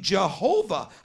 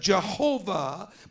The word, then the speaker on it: Jehovah: That could not possibly be the way it was pronounced, because there's no such sounds in Hebrew. But the Western world came to pronounce it Jehovah, but that comes, it may seem odd, but it comes from the name of God, Yahweh.